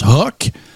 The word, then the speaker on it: Huc: Là, ça s'appelle, ça s'écrit HUC. Euh, ça, ça, se prononce HOC.